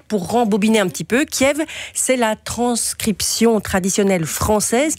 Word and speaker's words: Kiev: La prononciation, c'est Kiev. C'est aussi comme ça sur la VRT. C'est comme si, aussi comme ça à la SDF.